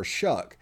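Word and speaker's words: Shough: As my earlier preview, whenever the bowl was announced, the pronunciation of the Texas Tech quarterback's name is Tyler Shuck, and he went to Oregon and not Purdue.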